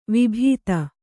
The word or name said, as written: ವಿಭೂತಿ